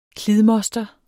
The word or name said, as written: klidmoster